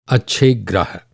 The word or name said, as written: ग्रह